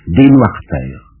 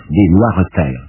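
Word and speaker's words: Desnoiresterres: Des Noires Terres. D-E-S, Des Noires Terres.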